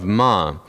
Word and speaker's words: Ma: the region of Ma. It's spelled like man, but you leave the N off, and it's Ma.